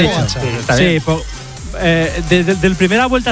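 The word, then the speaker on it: Pogačar: Juanma dice que es aguda, mientras que otros es drújula, así que aquí tenemos cómo se dice de verdad. – Tadej Pogacar.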